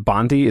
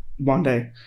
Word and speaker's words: Bondi: Is that Bondi? Is that how you pronounce that? – Yeah. Yeah, it's from the iMac, the iMac color.